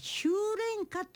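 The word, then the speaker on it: 秋恋歌: 修練歌って読むんだと思うの、はい、秋の恋の歌と書いて修練歌、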